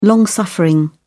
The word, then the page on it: long-suffering: British English